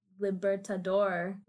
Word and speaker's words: Libertador: So when he did become homeless, he was living in San Cristobal, Tacheria, Venezuela, under the Liberator Bridge. That's not how you say it. Libertador Bridge, I hope.